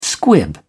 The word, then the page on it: squib: British English